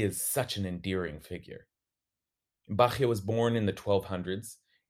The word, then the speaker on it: Bachya: I'm talking about Bachya ben Asher ibn Halawa, who's more commonly referred to as Rabbeinu Bachya, or Rabbeinu Bachaye, some pronounce it.